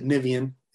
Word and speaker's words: Niviane: We were told that the way you pronounce it, Niviane, is the most popular way, South America, Europe, etc.